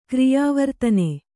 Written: ಕ್ರಿಯಾವರ್ತನೆ

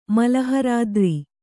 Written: ಮಲಹರಾದ್ರಿ

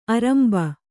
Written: ಆರಂಬ

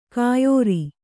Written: ಕೈಯಾರು

